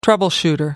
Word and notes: troubleshooter: American English

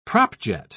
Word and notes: propjet: British English

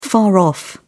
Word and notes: far off: British English

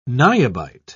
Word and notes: niobite: British English

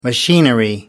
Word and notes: machinery: American English

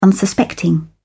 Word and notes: unsuspecting: British English

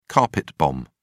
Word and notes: carpet bomb: British English

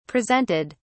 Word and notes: presented: British English

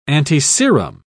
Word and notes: antiserum: British English

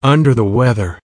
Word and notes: under the weather: American English

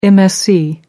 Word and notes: msc: American English

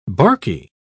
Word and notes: barky: British English

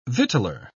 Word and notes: victualer: British English

